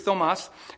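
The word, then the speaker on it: Thomas (Θωμᾶς): Apokrithē Thomas is how you say his name in Greek. Thomas.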